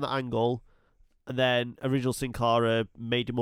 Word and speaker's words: Sin Cara: And Sin Cara.